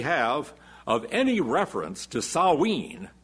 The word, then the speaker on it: Samhain: Now, those who will tell you that what the church actually did is they took this pagan festival, Samhain, Samhain is what it looks like, they pronounce it Samhain, and made it into a church holiday instead.